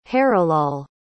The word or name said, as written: harilal